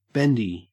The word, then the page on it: bendy: Australian English